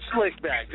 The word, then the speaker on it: Slick Back: Flick back. Did I say that right? Flick back. – It's a pimp named Slick Back, just like a Tribe Called Quest, all in one.